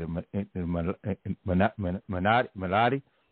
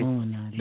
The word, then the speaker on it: Illuminati: You know, it's one of those things where we talk, what is it, a Minotti? how you pronounce that? – Illuminati.